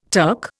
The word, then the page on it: Turk: Indian